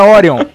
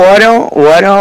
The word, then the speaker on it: Orion: É Orion, cara, é a porra do nome certo, a pronúncia, filho da puta.